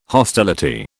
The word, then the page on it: hostility: British English